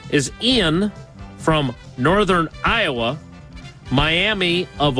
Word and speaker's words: Eian: Am I saying that correctly? – Pretty sure it's just Ian. Ian Elmer. – E-I-A-N Elmer.